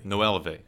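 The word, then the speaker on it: Noelvi Marte: Is it Nolvi Marte? – Nuelve, okay. – Now, Nuelve Marte, really one of the handful of best prospects, we think, in this year's pool.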